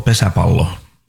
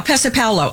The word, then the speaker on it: Pesäpallo: It's called Pesäpallo. – Yeah, Pesäpallo.